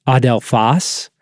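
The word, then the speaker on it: adelphos (ἀδελφός): And in the original text in Greek, just to be clear, that word is pronounced adelphos.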